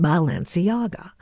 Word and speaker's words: Balenciaga: I want to say it right, Balenciaga.